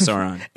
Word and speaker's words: Sauron: Is this the Eye of Sauron? Am I pronouncing that correctly? – Yeah, that looks like the Eye of Sauron.